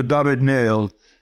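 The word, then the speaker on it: David-Néel: I loved Alexandra David-Niel, if that's pronouncing her name right. – Yes, that's right. The pronouncing great Alexandra David-Niel. She was one of the most unlikely, but also extremely well-informed travel explorer. She was actually in French, so she's not called David Neal, as some people think, but David Neal.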